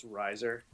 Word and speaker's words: Rizer: It's Rizer.